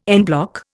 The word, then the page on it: en bloc: Indian